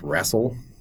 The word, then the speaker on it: Rasl: And the last one that I'll mention is called, I believe it's pronounced Rassel, R-A-S-L. This is kind of, like, sci-fi slash noir deal.